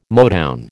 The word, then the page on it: Motown: American English